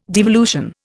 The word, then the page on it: devolution: Indian